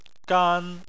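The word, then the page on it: gan: tone 1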